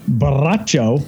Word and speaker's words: Borracho: Now, El Burro Borracho, because you're supposed to roll your R's when it's a double R, translates into the drunk ass.